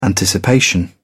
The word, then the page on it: anticipation: British English